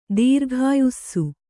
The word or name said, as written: ದೀರ್ಘಾಯುಸ್ಸು